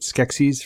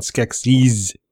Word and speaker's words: Skeksis: So Skeksis, so they're both spelled the same, but Skeksis for singular and Skeksis, Z sound for plural.